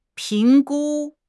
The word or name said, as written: 评估